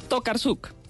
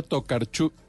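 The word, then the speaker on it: Tokarczuk: Tokarsuk. Tokarsuk. Mauricio. Copy paste. Igual. bueno, no es Tokarchuk, se pronuncia Tokarchuk, Tokarchuk, Tokarchuk, Olga Tokarchuk.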